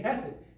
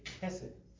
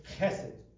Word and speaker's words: chesed: If you're American, you say chesed. If you're Hebrew, you say chesed, right? It's H letter in the Hebrew is a guttural, so you pronounce it in the back of your throat, right? Think French, ha-ha, you know, the way the French do it. The Hebrews say chesed.